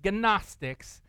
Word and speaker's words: Gnostics: It's spelled G-N-O-S-T-I-C-S, Gnostics, okay?